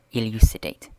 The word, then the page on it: elucidate: British English